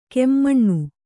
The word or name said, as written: ಕೆಮ್ಮಣ್ಣು